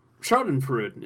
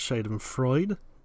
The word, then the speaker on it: Schadenfreude: Schadenfreude is what they call this, I believe. – I believe it's pronounced Schadenfreude, but sure.